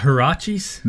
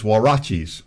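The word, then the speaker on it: huaraches: Is it not hirachis? No, it's warachis.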